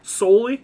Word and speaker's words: Solely: Solely. Am I saying that right? – Yeah. – Solely? – Yep. Like your feet.